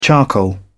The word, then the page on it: charcoal: British English